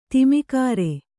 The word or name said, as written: ಧೂಮಕರ